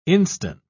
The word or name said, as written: instant